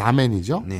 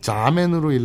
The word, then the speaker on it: Samen: 독일어 S A M E N 그러니까 사멘이죠. 네. 를 S를 Z처럼 읽어서 자멘으로 읽으시면 대충 알아들어요.